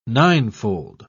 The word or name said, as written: ninefold